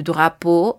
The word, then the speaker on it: drapeau: En francés, le drapeau, D-R-A-P-E-A-U, y si hablamos en plural, le agregaremos una X al final para decir las banderas, le drapeau, pero no habrá impacto a nivel pronunciación.